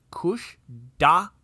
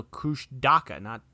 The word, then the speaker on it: Kushtaka: The legend of the otter-like shape-shifting Kushtaka, pronounced Kushdaka, so Kushdaka, not with the hard T, with the D, is one told by locals as one possible explanation for the disappearances in the region.